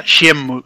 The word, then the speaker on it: Shenmue: Es que he oído Semu, se mue, se he oído por pues, de cada movida, ya no sabía cómo decirlo, tío. – En principio, según el katakana que te sale en la carátula, Shemu Y ya está.